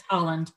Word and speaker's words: Haaland: I don't I know if that's Haaland. how you pronounce her. I, I, it's Haaland.